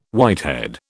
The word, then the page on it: whitehead: British English